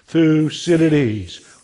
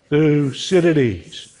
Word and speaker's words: Thucydides: Thucydides. One more time. Thucydides.